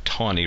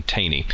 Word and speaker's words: Taney: But one of these days, I'll track down somebody who'll tell me definitively why we pronounce it Tawny rather than Taney.